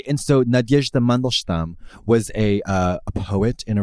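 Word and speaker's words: Nadyezhda (Надежда): And you say it's 3 syllables. Nad. – Nad. – Yezh. – Yezh. – Da. – Da. – Nadyezhda. – Nadyezhda. – Perfect.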